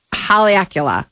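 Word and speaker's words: Haleakalā: Like everybody that goes is like, you've got to make it to the top of Haleakala. I can't never remember how to pronounce it. I'm the worst. And they Haleakala.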